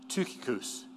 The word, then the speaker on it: Tychicus: An upsilon is what we usually have as a Y, the Greek letter. But it's always pronounced like U or U. So that T-U is Tychicus.